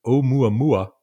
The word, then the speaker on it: Oumuamua: And I'll say that the one that's gotten Avi Loeb and now Galileo a lot of attention is a mysterious object called, if I say this right, Oumuamua, which I think is a Hawaiian word meaning messenger from afar arriving first. Did I say that right? – That's right. Yep, that's correct.